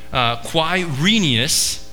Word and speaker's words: Quirinius: This was the first census that took place while, and this is a fun word, Quirinius, is how you pronounce that, was governor of Syria, and everyone went to their own town to register.